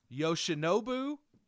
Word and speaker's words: Yoshinobu: The performance that he had, I feel like I'm going to make you say his name correctly today. – Okay. – Yoshinobu. – Yamamoto. – Nailed it.